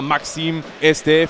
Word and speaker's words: Maxime Estève: Aber es war Maxime Estève. Nico hat völlig recht. Mein Französisch war schon ein bisschen eingerostet. Also ja. So wird der Kollege, den sie aus Burnley geholt haben, ausgesprochen.